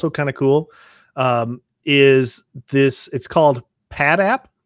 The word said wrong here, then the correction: Patatap: Patatap. Sorry, I pronounced I missed a at in there. Patatap, and you do need the sound on for this.